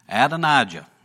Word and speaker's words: Adonijah: Then Adonijah is how that's pronounced, the best that I can come up with.